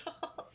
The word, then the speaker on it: Hohl: Yeah, he changed it to H-A-L-L. And it probably was still pronounced Hall, H-O-H-L, but nobody would know that.